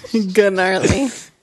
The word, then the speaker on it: gnarly: Gnarly. – English is so weird. – Oh, gnarly. Really? Horchata? – No, you don't pronounce the H. – Exactly, you don't pronounce the G.